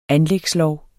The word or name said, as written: anlægslov